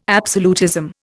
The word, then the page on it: absolutism: Indian